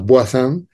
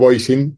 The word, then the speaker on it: Voisin: Boisson. – francés. Croissant, no, Boisin. – Ah, vale. – Bueno, Boisin, para los amigos, para los latinos.